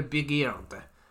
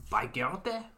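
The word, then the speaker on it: Bigeard: Marcel Bruno Bigard. Bigarde? Bigarde bon. Bodon Bigico bon. Various ways of pronouncing it, depending on whereabouts in France you are.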